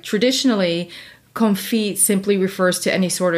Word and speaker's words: confit: Yeah, confit. – Confit. – Yeah, so the T is actually silent.